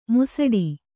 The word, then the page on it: ಮುಸಡಿ: musaḍi